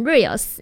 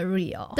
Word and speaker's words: Real: 对，没错， Real 这一首这个专辑都一直让我想要念成 Reels，因为呢最近就是 IG 滑太多了，我就一直很常念成它是真实的 Real。 – 对，它不是 R-E-E-L 也不是 R-E-E-L-S，它是 R-E-A-L，真实这样子。